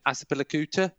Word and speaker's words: Azpilicueta: And I bought in Azpilicueta.